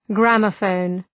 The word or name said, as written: gramophone